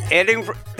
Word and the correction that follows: Edrington: Redden. How you pronounce that? – Edrington.